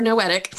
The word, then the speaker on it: Noetic: John always says this, the Noadic covenant. Is that the right way to say it? – Is it Noadic? I've always thought it was Noetic. Is it not Noetic? Like et? – It's Noetic, one of Noetic.